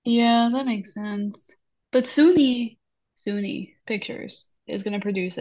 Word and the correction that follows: Sony: Wait a minute, did you just pronounce Sony as Suni Pictures? – Oh my God.